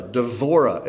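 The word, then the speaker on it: Devorah (דבורה): Devorah is how it's pronounced in Hebrew, Devorah.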